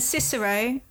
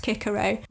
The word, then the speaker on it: Cicero: This is completely unrelated, but we pronounce Latin all wrong. Like Caesar, I'm pronouncing wrong as well. It should be Kaiser because the Latin does not have soft C's. We have softened them, so it should be Cicero and Kaiser.